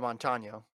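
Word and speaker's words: Montaño: Alicia Montano or Montan? Did I say that right? Alicia Montano? – Okay, yeah, I mean, Alicia Montano is how I say it.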